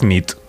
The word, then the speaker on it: nit: Y noche es nit. – Muy bona bien. – Nit. Bona nit. Es verdad. – Bona nit.